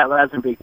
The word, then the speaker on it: Lazenby: George Lansby. He was an Australian. – Spell the last name. – I can't spell it. Lansby. – George Lansby. Oh, Lazenby. Lazenby. – Yeah. Yeah, Lazenby.